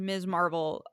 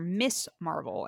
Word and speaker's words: Ms. Marvel: Um, despite the name of the show being Miss Marvel, pronounced Ms. Marvel, uh, the actor playing Kamala's father calls her Miss Marvel, an accurate but odd oh.